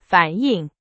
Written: 反映

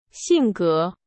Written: 性格

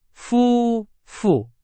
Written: 夫妇

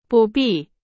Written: 不必

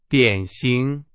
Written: 典型

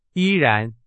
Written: 依然

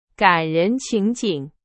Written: 情景

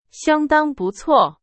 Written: 相当

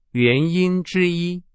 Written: 之一

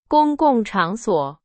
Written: 场所

